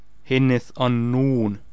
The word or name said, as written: Henneth Annun